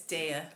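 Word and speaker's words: Daiya: Daya or diet? – Vegan. Daya. It's Daya. – I don't know. Daya. Oh, we're saying it wrong.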